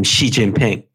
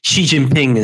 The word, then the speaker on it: Xi Jinping: It all depends on, of course, how you romanize a Chinese character, because what we're talking about is how we pronounce a Chinese character. But Xi Jinping is the right way to go.